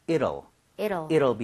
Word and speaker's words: it'll: 이 T 사운드가 약간 flat이 나요. 그러니까 늘 비슷하게 발음이 돼요. – 네. – 그래서 저는 it will 이렇게 하는 것보다는 it'll be over 이런 식으로 이럴 수가 하는 것처럼 이렇게 부드럽게 읽어달라는 거예요.